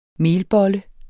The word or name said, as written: melbolle